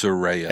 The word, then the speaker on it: Soraya: I thought it was Soraya. – It's Soraya. – Everybody's saying Soraya. Soraya. Sorry. – Nope. She even came out and said, like, phonetically, she spelled that out. It's Soraya.